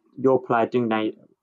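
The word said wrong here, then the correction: Digne: how do you pronounce his name? – It's Dean. – So it's Dean.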